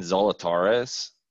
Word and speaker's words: Zalatoris: Actually, before I give that one out, Kuchar is plus 9,000, 9K, and Zalatoris, I think that's how it's pronounced, boys.